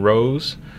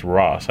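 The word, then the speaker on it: Rohs: The original opera house was built by Herman Rose, pronounced Ross.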